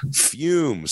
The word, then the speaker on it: Fume: And of course, that is Fume, spelled F-U-M, pronounced F-U-M-E.